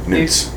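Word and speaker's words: Knut: Since he mispronounced Hogwarts money? – Knots. – Newts. – Newts.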